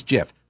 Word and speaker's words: GIF: That's the way you pronounce that too, by the way. I'm not getting into that fight, but it is GIF.